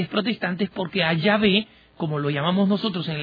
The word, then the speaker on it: Yahvé: Un protestante es reconocido porque le dice Jehová, ¿no? Y que es un nombre equivocado, es una colocación equivocada de las vocales.